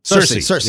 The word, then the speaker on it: Cersei: Sesame Street just released a Game of Thrones video with, is it pronounced Cersei? – Cersei. – Yes.